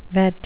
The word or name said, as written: vet